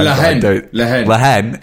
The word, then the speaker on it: La Haine: No, La Haine.